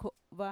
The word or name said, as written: ko'va